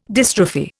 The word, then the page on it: dystrophy: Indian